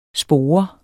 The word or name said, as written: spore